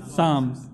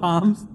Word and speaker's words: Psalms: Psalms. It's not Palms, Psalms. Huh? It's actually spelt with a P though.